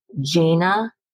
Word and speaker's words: Jaina: And she's written it with a co-author who is a much beloved and often mentioned expert therapist, whose name is Jaina, because it rhymes with Dana. So I'm trying to make sure that I got it right. I think it's Jaina Richardson.